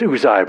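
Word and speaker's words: Uzzi: I pronounce it Uzi. They say it's Uzai, but I think it's Uzi. Because it's son of Buki, son of Abishua, son of Phinehas, son of Eleazar, son of Aaron, the chief priest.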